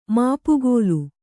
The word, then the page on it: ಮಾಪುಗೋಲು: māpugōlu